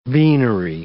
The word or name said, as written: beanery